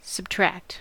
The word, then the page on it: subtract: American English; [səb.ˈtɹækt]